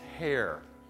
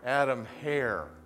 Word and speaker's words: Hare: And though his name is spelled Heary, H-E-A-R-E, let's get his pronunciation of his name right. It's Hare. Adam Hare, okay?